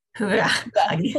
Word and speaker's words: hygge: hygge. – hygge.